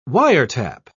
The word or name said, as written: wiretap